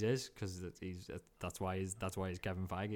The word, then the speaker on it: Feige: So first, Kevin Feige, as you like to call him, because you know personally, Kevin Feige rhymes with beige.